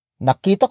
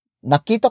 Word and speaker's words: nakita: The first word is nakita. Nakita. Nakita. Nakita is spelled as N-A-K-I-T-A.